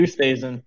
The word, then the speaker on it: Oosthuizen: Hadwin, Reed, Luis Oosthuizen. Did I pronounce that correctly? – Yeah, Louis Oosthuizen.